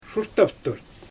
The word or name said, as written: ʃurtaftur